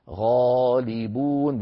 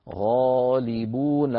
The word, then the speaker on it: غالبون: غالبون بوزن فاعلون، لذلك علماؤنا زادوا الألف الصغيرة التي يسميها الخطاطون الألف الخنجرية غالبون، لا بد من نطقها.